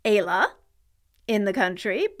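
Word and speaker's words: Éala: It's actually spelled E-Fada, A-L-A, and it is pronounced Ayla.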